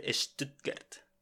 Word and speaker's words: Stuttgart: Sí, y la pronunciación buena de Stuttgart es Stuttgart.